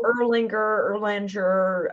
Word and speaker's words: Erlanger: what are some of the mispronunciations? – Oh, Erlanger. There's many. Yeah.